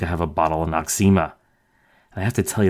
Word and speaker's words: Noxzema: No-X-Zema, no eczema, or as we normally pronounce it, Noxzema.